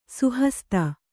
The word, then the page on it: ಸುಹಸ್ತ: suhasta